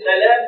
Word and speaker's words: سلام: سلام هل هو ابن سلام ولا ابن سلام؟ من ارجح ما يحتج به من يقول انه سلام روايه يرونها عن محمد بن سلام انه قال انا محمد بن سلام بالتخفيف فكان الموضوع اصلا كان سائد في زمانه كان مجتهد خلاف في نطق اسم ابيه هل هو سلام ولا سلام؟